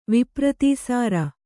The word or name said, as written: ವಿಪ್ರತೀಸಾರ